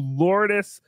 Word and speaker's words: Lourdes: When my neighbor was sick with cancer, his family took him to Lourdes, France for a cure.